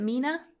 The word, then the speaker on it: MENA: Representatives from Eval Youth, MENA, is that how I pronounce it? MENA? – Yes.